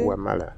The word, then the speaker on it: Kiamala: Kiamala.